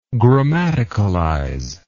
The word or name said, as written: grammaticalize